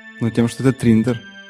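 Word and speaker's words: 3inder: Э, называется Триндер. Слышал? Триндер? Да. – Нет. – Э, пишется э, три как цифра, а потом эм, I-N-D-E-R. Ну, как ну, Триндер. Ну, по сути, как Тиндер, только Триндер.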